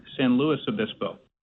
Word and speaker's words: San Luis Obispo: You've probably never been here, but we pronounce it here San Luis Obispo.